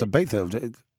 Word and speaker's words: Ibiza: I think the fact that Wave the Bill, number 3, has joined inside its main rival, Mr. Ibiza, number 4, is that how you pronounce it? Ibiza? Not yeah. Ibiza? – No, yeah. It's Ibiza.